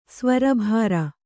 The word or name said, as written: ಸ್ವರಭಾರ